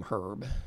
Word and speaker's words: Herb: His name is Herb, H-E-R-B, right? But it's also Herb. – Yes. – So, do you see my concern? It's spelled the same but pronounced differently.